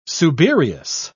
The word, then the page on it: subereous: British English